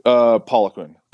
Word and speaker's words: Poliquin: Is it Poliquin? I don't know how to pronounce the last name. – Poliquin.